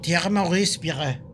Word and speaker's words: Thierry Maurice Pierre: Que yo me imagino que se pronuncia algo así como Thierry Maurice Pierre. – Este te salió perfecto. – Así se pronuncia exactamente.